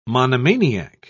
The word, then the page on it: monomaniac: British English